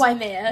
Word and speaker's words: Waimea: And then you had said you went to Waimea, is that how you pronounce Waimea.